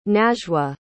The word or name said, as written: najwa